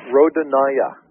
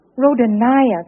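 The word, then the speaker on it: Rodonaia: And I remember seeing George, and I'm never sure how to pronounce his name, Rodonian? – The Russian. – Yes. – Rodonaya. – Rodonaya, there we go.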